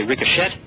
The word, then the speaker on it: ricochet: Till I was again with you, a song called Ricochet, and that is spelt R-I-C-O-C-H-E-T. I've sometimes seen that phonetically on some record labels, and they spell it out just to make sure that people don't say ricochet, they say ricochet.